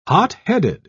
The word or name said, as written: hotheaded